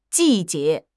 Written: ji4 jie2

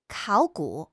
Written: 考古

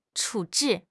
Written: 处置

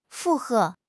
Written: 附和

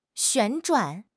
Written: 旋转

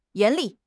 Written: 严厉

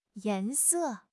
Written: yan2 se4

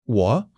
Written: wo3

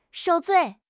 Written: 受罪

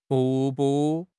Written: 瀑布

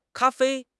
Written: ka1 fei1

